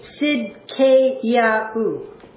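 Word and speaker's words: Sidkeyahu (צדקיהו): If we were to pronounce it in Hebrew, it would be Sidkeyahu.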